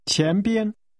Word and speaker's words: 前边: 前边。边。前。前边。 – In front of is pronounced with a rising tone. – 前。 – And a high level tone. – 边，再说一次。 – 前边，地铁站的前边。 – Say, in front of the subway station, or literally, subway station in front of. – 地铁站的前边。